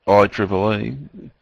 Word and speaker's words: IEEE: The Institute of Electrical and Electronic Engineers, or IEEE, pronounced it IEEE, maintains the networking standards, including Ethernet and wireless standards.